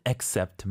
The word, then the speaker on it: accept: I think it's safer to say accept.